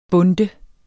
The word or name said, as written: BUNDTET